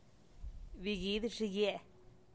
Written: bigidyxigie

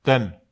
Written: ten

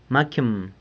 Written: macyëm

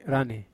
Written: rane

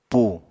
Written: pu

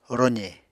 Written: roze

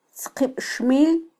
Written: tzqibxmil

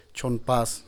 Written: txonn pas

